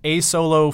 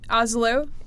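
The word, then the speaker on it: Asolo: it's A-Solo Fugitive GT Boots. Does that sound right? – Almost. Yeah, so, Oslo is how that's pronounced, and I think that is the namesake of the town where they are created.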